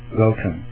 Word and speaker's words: Galtung: Gatung, se escribe G-A-L-T-U-N-G, Gatung,